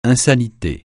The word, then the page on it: insanité: francais